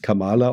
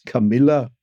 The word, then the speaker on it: Kamala: Trump hat schon vor Jahren versucht, sich über sie lustig zu machen, indem er nicht sie als Kamala ausgesprochen hat, sondern als Camellia oder als Camilla.